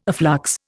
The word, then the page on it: afflux: Indian